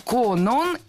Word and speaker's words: Конон: Конон – это имя, и ударение в этом слове падает на 2-й слог.